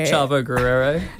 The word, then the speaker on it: Chavo Guerrero: Mountain Goats, Legend of Chavo Guerrero. I've probably pronounced that wrong. – You're pretty close. – Okay. – Legend of Chavo Guerrero.